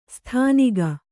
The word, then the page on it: ಸ್ಥಾನಿಕ: Kannada